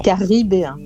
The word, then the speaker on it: caribéen: jazz et de rythme caraïbéen. L'ai-je bien prononcé Caribéen.